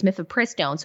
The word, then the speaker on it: mifepristone: I always get, uh, I always forget which syllable needs the emphasis when we're talking about that horrid abortion pill. It's mifepristone.